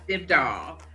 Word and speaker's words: Dybdahl: Dybdahl.